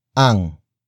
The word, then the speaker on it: ang: The next word is ang. Ang. Ang. Ang is spelled as A-N-G.